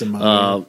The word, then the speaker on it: Zamata: I never pronounce her name correctly. – it's Zamata.